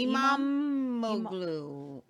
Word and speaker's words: İmamoğlu: İmamoğlu, how do you say that? – Imam. – It's like an imam. Wouldn't you say İmamoğlu?